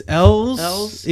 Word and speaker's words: Elwes: I think it's Elwes. Even though it's E-L-W-E-S.